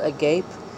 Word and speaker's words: agape: Agape, A-G-A-P-E, agape. It's kind of like, it looks like it's spelled agape, but it's pronounced agape, and it's a Greek word, and it means universal love, basically.